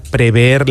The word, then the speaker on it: prever: pudiera prever la magnitud de lo que ocurrió. – Prever, cuidado. – Muy bien pronunciado, además.